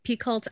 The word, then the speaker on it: Picoult: Amazing author Jodi Picol or Picou. Picoult. I never knew how to say her last name, but Jodi Picoult is great, great author.